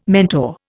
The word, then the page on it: mentor: Indian